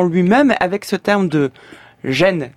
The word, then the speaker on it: Ren: de on ne dit pas reine mais gêne,